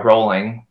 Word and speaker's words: Rowling: Rowling uh, rolling like bowling.